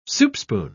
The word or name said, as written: soupspoon